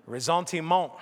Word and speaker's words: ressentiment: and I'm going to butcher the French pronunciation, but it is known as ressentiment. Ressentiment. I checked with Amy in between, who's studied French. She said I was close, so that's good.